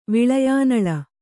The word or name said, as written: ವಿಲಯಾನಲ